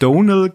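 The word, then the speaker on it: Domhnall: Das M spricht man nicht. – Richtig. – Donal Gleason. Weil der hat nämlich so ein YouTube-Video gepostet oder irgendwie ist es veröffentlicht, da hat er gesagt, the M is only there to confuse the Americans.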